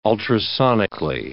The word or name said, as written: ultrasonically